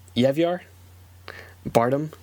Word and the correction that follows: Javier Bardem: Quick editor's note, now that I actually have the name in front of me, it is pronounced Javier Bardem.